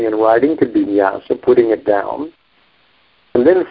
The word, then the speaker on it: nyasa: The word that's used is nyasa, you know, with the long A, nyasa.